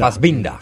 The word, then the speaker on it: Fassbinder: Fassbinder. – Fassbinder. – Vendría a ser. – No como el otro, no como el actor ese... – Fassbinder, de 12 años de clavitud. – Sí. Fassbinder nació en el 45,